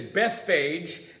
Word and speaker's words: Bethphage: The majority of scholars say that the way to pronounce the name of this village is Bethphage.